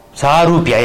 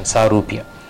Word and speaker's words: Sarupya: Swarupa. Not Sayuja. Sayuja is the Brahmajyoti. Sarupya, yeah, that's the right pronunciation. Sarupya.